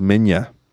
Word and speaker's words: Minya: A lot of fans detest Manila, son of Godzilla. And the guy says, actually, it's pronounced Minya.